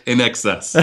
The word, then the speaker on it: INXS: How do you pronounce that band that came out with that song in the 80s, I Need You Tonight? – Tell me the band first and I'll tell you how to pronounce it. – It's spelled I-N-X-S. – In excess.